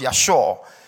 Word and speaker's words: Yashah: I also found out that deliverance also stemmed from another word called Yashor, spelled Y-A-S-H-A-H, but pronounced Yashor, which also means, this is a different type, means to be preserved, saved, rescued as well.